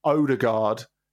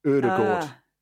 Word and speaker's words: Ødegaard: So it's not Odegaard, it's Odegaard. I think the umlauted O at the beginning is very, very important. Although it's not an umlaut, it's just the same sound.